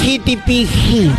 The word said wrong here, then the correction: Chat GPT: No, GPT. T no, T. Demórate un poquito, pero pronuncia bien.